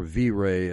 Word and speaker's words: Viret: You just mentioned Viret. Or V-Ray, as some Americans pronounce it.